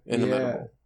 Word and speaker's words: inimitable: How do you say that word? Inimitable? Inimitable? Inimitable, yeah.